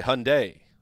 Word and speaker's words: Hyundai: The Around the NFL podcast is driven by Hyundai, which rhymes with Sunday.